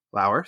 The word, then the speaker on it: Lauer: You can also follow me on Twitter at Lauer underscore Ryan, Lauer spelled like Lower.